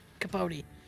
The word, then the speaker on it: Capote: I want to say this right. Truman Capote? – Capote.